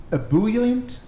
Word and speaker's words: ebullient: Yeah, I never pronounce it correctly. Ebullient. It's like EB. – I think you got it.